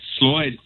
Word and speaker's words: Sloyd: Now, first, my very first question, am I pronouncing this right? Sloyd? – That's correct. Sloyd. Sloyd Experience.